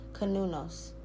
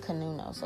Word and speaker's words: Cernunnos: Kanunos is how his name's pronounced. Kanunos. And it's spelled like C-E-R-N-N-U-N-O-S or something like that. But it's pronounced Kanunos, okay?